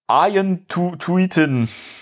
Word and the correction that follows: Arjen Tuiten: das hast du falsch ausge ausgesprochen. Ach was. Ja. – Wie spricht man ihn denn aus? – Ar Ar Arjen Tuiten.